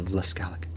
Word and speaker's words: Vlaskalic: I want to welcome Daniela, I'm going to say your name wrong, your last name. Vlaskalic? – That's pretty close. – Close? – Very close. – What is your last name? – Vlaskalic. – Vlaskalic.